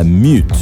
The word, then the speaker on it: Meute: Y esta es una banda llamada Mute. Se escribe Meute.